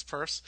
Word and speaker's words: Peirce: So if you've ever wondered, it's not Peirce, it's Peirce.